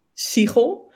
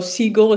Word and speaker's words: Siegel: Siegel. But Siegel. My dad's actually American, so Siegel is actually quite correct as well.